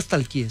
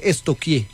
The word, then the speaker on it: stalkear: Porque no se dice nunca stalkeé, se dice estoquié. – ¿Estoquié? Sí. – Pregúntele a la profe, verá. A mí una vez estamos estoqueando. – Sí, estoqueando. Yo, ay, estoqueando. Pero se escribe stalkear. Es estoquear.